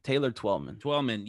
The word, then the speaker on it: Taylor Twellman: Is it Taylor Tillman? – Taylor Twellman.